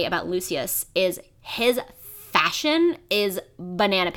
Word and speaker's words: Lucius: Jim Dale pronounces it Lucius, and I trust him in all things.